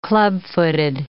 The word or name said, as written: clubfooted